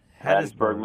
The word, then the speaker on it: Hattiesburg: Hattiesburg, I say that right? I don't know if I ever say it right. – That's right. Hattiesburg, Mississippi.